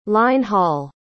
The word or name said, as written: line-haul